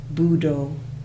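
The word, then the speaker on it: Buddho: Like in the Thai forest tradition, they use the word budo, which is it's the same word as Buddha. And so just breathing in the first syllable, bu, do, bu, do.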